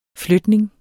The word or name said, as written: flytning